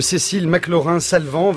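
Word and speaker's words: Cécile McLaurin Salvant: Cécile McLaurin-Salvant, 26 ans et dont le nom qu'il faut prononcer à la française est déjà sur toutes les lèvres, notamment pour le festival Jazz et la Villette qui commence demain à Paris.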